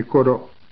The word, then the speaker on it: イコロ: はい、ここでアイヌの民具イコロ宝刀宝刀と書いてありますね。